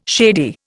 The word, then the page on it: shady: Indian